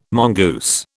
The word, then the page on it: mongoose: British English